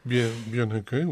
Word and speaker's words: Björn Höcke: La revue de presse allemande à présent, Björn Höcke, c'est la bonne prononciation ? – Exact, Björn Höcke, oui.